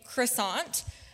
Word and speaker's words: croissant: How do you pronounce the word C-R-O-I-S-S-A-N-T? Okay, I've heard a few different things. I think most people would probably say croissant. In our household, Mom wanted us to learn the correct French pronunciation, which would be croissant.